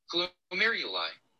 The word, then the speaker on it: glomeruli: Glomeruli.